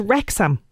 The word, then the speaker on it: Wrexham: The streaming, which is Disney Plus this week, and it's Welcome to Wrexham. Am I pronouncing that right? – That's right, yes. Yeah.